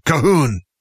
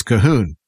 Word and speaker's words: Calhoun: And they looked at me and shook their head and they said, "No, not Calhoun, Cahoon." And so that's my Gaelic name is Cahoon. And it's even spelled much different. You don't even want to know the Gaelic spelling. But that's how they pronounced it, was Cahoon.